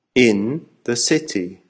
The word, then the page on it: in the city: American English